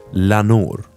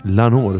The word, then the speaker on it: Lenoir: JB Lenoir, eller JB Lanoir som han själv uttalar det.